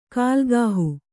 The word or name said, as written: ಕುಲಗೇಹ